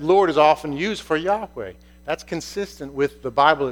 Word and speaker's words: Yahweh: it comes out Jehovah. But that's not how to say it. It's Yahweh.